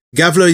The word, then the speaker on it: Gävle: I don't know how to pronounce this, but it says here it's spelt the Gävle goat, perhaps? – Yes. Gävle is the name of the city where it is.